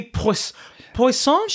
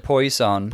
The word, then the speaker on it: Poisson: Next up, we got Poopy Poisson? – She pronounced it poison. – Poison. – Because we looked at it and we thought like the French word for fish, poisson, which would be a drag queen joke, wouldn't it?